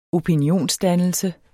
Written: opinionsdannelse

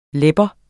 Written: lebbe

